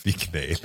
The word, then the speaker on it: Vignale: godt, at øh, altså, jeg havde sådan en vignale i påsken, eller Vig udtale udtalte, som jeg har lyst til. Øh, mega proppet med udstyr. – Dejlig vogn. – Vignale. – Det hedder den egentlig. Vignale.